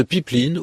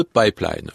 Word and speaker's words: pipeline: On a parlé pendant assez longtemps de pipeline ou de pipeline. Le mot, on l'entend en français depuis assez longtemps, hein, il apparaît vers 1880-90. Les deux prononciations ont été entendues,